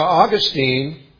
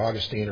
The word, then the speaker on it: Augustine: Now, Augustine, and I looked this up in the dictionary, and you can pronounce his name either Augustine or Augustine, Augustine or Augustine. I say Augustine, so it's probably Augustine.